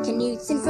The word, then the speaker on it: Knutson: But I want you to pronounce the word Knutson like all the other words that start with an N, like new and near, okay? – Okay. There's something new at a store near. Knutson frozen low-fat yogurt now is here.